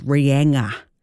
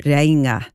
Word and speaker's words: Reinga: The pronunciation that irks me the most is Cape Reinga, often pronounced as Rianga, Cape Reinga.